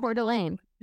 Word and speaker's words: Coeur d'Alene: So please fix this, because I am like the worst person ever that say Coeur d'Alene. Is that pronounced? – Coeur d'Alene.